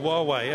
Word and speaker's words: Huawei: Well, Huawei, as it's pronounced, is known very well to our customers.